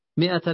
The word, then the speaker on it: مائة: هذه الألف تكتب ولا تنطق. مائة إلا واحدا من أحصاها دخل الجنة.